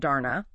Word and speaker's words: Dana: It centers on what the workbook calls Dana, that's pronounced Darna.